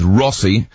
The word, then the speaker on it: Rossie: Start your text with Rossi, R-O-S-S-I-E is the way I spell it, R-O-S-S-I-E.